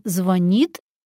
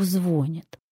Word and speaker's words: звонит: Мы должны очень хорошо помнить об этом, когда думаем, он нам звонит или, не дай бог, звонит. Вот я предлагаю на тех, кто звонит, не реагировать и трубку не брать, и отвечать только тому, кто звонит. Подведем итог. В глаголах ударение падает на окончание.